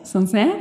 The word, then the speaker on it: Sancerre: Also es war ein Sauvignon Blanc ich muss kurz überlegen, ich kann es nicht so gut aussprechen, Sancerre. – Sancerre? – Ja, genau.